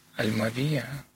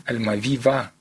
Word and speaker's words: Альмавива: я уж боюсь неправильное ударение поставить, Альмавия. – Альмавива.